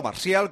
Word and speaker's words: Martial: y sin duda este Martial, o Martial, como así se pronuncia en francés, el jugador del Manchester United, creo que sería un futbolista que le daría un salto de calidad indiscutiblemente al ataque del Sevilla.